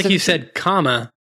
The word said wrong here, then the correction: karma: Karma. – Karma. Yes. Okay. That's the one. My bad. – Karma. That's how we pronounce it, which is different to comma, because comma is a punctuation mark.